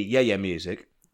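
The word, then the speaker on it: yé-yé: Which is spelt Y-E-Y-E. Yeah, yeah music.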